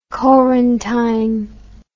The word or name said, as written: Corantijn